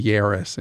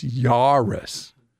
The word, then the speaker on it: Yaris: Kim of our crew has, I always say Yaris, and she says, no, it's Yaris.